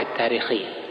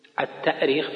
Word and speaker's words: التأريخ: من حيث الأصل الاشتقاقي التأريخ التأريخ بالهمز لأنها أرخ يؤرخ الهمز أصلي فيه أرخ يؤرخ تأريخا وأما التاريخ فهو تسهيل والتسهيل موجود في القرآن في في الهمز في مواضع كثيرة عند بعض القراء مثلا يعني معروف قراءة آه، نافع مثلا للتسهيل وعدم القراءة بالهمز في مواضع كثيرة وأيضا تنطق التوريخ لأن الهمزة تبدل بواو في بعض المواضع وبعض أهل العلم يسميه التوريخ توريخ يعني كأنه أرخ جعلها ورخ يورخ توريخا لكنها غير شائعة وإن استعملها بعض أهل العلم